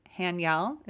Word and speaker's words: Haniel: And if you'd like to look her up to learn more about this archangel, it's spelled H A N I E L Hanyel is how I'm pronouncing it.